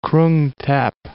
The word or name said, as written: Krung Thep